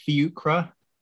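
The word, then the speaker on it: Fiacre: So yeah, this guy's name is Fiucre, not something else.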